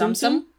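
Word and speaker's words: Tsum Tsum: And everywhere they had in the Disney theme parks, I'm probably pronouncing this wrong. T-S-U-M. I'm presuming it's pronounced Tsum-tsum. – Yeah.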